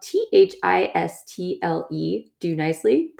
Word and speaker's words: Thistle Do Nicely: It is spelled THISTLE, do nicely, pronounced thistle do nicely instead of thistle, thistle, which I laughed so hard when I turned it on and I heard them say that.